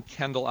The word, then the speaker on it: Kendall: Well, if you Google the name Kendall Almirico, I'm the only one in the entire world, but my email...